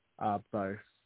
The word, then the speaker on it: Beau: Bo. – How do I spell that, man? – B-E-A-U. – B-E-A-U.